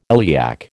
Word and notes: iliac: American English

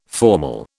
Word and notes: formal: British English

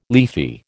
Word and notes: leafy: American English

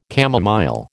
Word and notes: camomile: American English

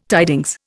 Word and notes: tidings: Indian

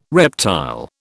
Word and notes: reptile: British English

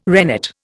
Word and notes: rennet: Indian

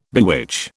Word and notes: bewitch: British English

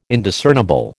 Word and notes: Indiscernible: American English

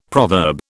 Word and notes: proverb: British English

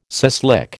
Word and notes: suslik: American English

